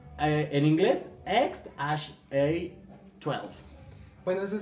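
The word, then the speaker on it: X Æ A-12: Musk dijo que el nombre de su hijo se pronuncia X A12.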